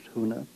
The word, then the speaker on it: huna: In Hawaiian, the word for secret is huna, which is spelled H-U-N-A, huna.